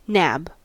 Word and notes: nab: American English

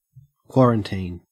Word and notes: quarantine: Australian English

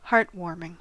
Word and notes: heartwarming: American English

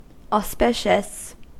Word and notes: auspicious: American English

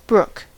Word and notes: brook: American English

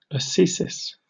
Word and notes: ascesis: British English

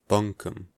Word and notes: bunkum: American English

